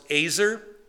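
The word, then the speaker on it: azer (עזר): It's pronounced azer. Azer as if you've been naughty and the cop has found you. He uses a taser.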